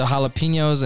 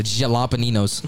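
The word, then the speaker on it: jalapeños: The jalapeninos. – Did it have pickled DiGallo on it, too? – I had an extra syllable in that.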